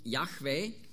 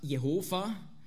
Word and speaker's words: Jahwe: Und darum hat auch Luther ganz korrekt in seiner Übersetzung überall, wo Jahwe oder wie man früher gesagt hat, Jehova, ist eigentlich das Gleiche, Nur ist Jehova nicht ganz richtig ausgesprochen.